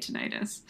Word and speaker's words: tinnitus: Mel says tinnitus. – Well, that just sounds British. Like, I do think North Americans say tinnitus.